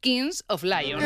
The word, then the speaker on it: Kings of Leon: Bueno, y luego en el Mad Cool, claro, justo actuaba en el momento del partido España-Bélgica el grupo estadounidense Kings of Leon.